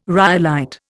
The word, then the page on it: rhyolite: Indian